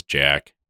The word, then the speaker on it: Jacques: I think it's, like, it's spelled like the French Jacques, but it is Jack.